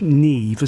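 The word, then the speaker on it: Niamh: Aided by Adrian, and I think it's pronounced Neve, isn't it? – Yes. – Because it's spelt something like Neopa. – Yeah. – But it's Neve, I think.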